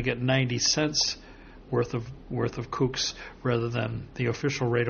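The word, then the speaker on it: CUC: Well, there are stores that accept the kooks, which are, that's K-O-O-K-S, It's C-U-K-S. – I know, but the way it's pronounced. What is it really? – Cuban pesos. – Right, but it's pronounced kooks, which is K-O-O-K-S.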